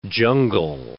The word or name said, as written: jungle